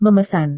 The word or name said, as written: memesan